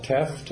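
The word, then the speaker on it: Tefft: My name is Carvel Teft.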